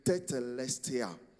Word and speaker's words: tetelestai: The English translation for the Greek, it is finished, word called tetelestia. I spell it for you, T-E-T-E-L-E-S-T-A-I.